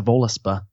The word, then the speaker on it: Völuspá: So my first question is, did I pronounce the title of the poem correctly? – Yeah, that's right. The Völuspá.